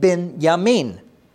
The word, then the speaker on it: Benjamin: Now, maybe you've heard of the name Benjamin. Well, in Hebrew, you would pronounce that Ben-Yamin.